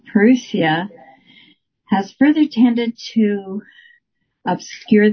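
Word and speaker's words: parousia: Yeah, Perusia, with the emphasis on Ru.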